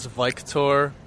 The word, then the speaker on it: Viktor: My name is Victor Paris, complete stranger. – Victor, can you spell that? – V-I-K-T-O-R. – So it's like Victor, but... like, in a different way.